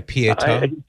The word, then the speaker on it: Pietà: I know people talk about images and artwork, but the sculpture of the Pietà, is that how you say it? The pity? – Yeah, Pietà.